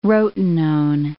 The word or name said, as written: rotenone